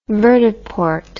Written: vertiport

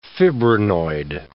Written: fibrinoid

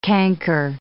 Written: canker

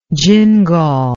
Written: gingal

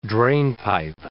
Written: drainpipe

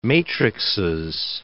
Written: matrixes

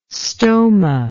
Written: stoma

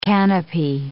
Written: canopy

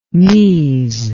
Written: mise

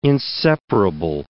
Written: inseparable

